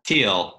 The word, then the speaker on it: Thiel: Thiel. Well, it depends. Some people say Thiel. Like if you're a Thiel in the Midwest, they say Thiel. If you're anywhere else, they say Thiel.